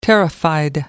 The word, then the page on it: terrified: American English